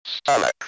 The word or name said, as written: stomach